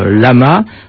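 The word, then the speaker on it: La (བླ): Au Tibet, le moine bouddhiste se nomme Lama, de Ma, qui veut dire homme, et La, écrit avec un B qui ne se prononce pas, Bla, supérieur.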